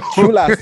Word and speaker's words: chulas: Some cholo, how you say it in Spanish, chulo. – Chulas. Yeah, chulas.